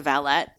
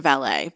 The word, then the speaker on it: valet: has a valet. Mm-hmm. He calls it a valet, but Archer taught me that it's a valet if it's a manservant and you pronounce the T.